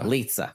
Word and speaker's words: Lizza: Is am I saying his last name right? – Walter Lizza. – Lizza. – Rhymes with pizza.